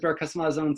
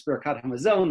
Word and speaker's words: Birkat HaMazon: I would pronounce as Birkas HaMazon. Someone else might pronounce as Birkat HaMazon, the grace after meals.